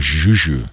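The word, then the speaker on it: Zhu Zhu: Z-H-U. – Oh, Juju.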